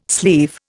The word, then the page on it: sleeve: Indian